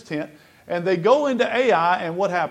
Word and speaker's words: Ai: You notice that when Alva read this, he said, apparently that's the right way to say it. I, from my youth, have called it AI, and you just have to live with me saying that wrong if it's wrong.